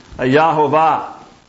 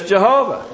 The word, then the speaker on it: Jehovah: The pronunciation in Hebrew is most certainly very close to the three-syllable name Yahovah. In English, we pronounce the Y as a J. But in Hebrew, that J is converted to a Y. But we speak English, not Hebrew, and so the correct pronunciation is Jehovah.